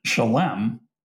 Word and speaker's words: Shalem: Our students here, by the way, you mispronounced Shalom College. – I'm sorry. – So the name of the college that where I'm the president, Shalem, means whole or complete.